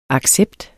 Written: accept

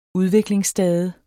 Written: udviklingsstade

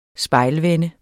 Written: spejlvende

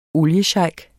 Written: oliesheik

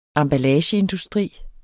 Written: emballageindustri

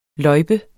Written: løjpe

